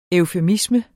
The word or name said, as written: eufemisme